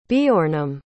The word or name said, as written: Beornham